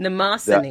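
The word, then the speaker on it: Mnemosyne: Mnemosiny. Mnemosiny. – Mnemosiny. The M, the first, the first M is silent.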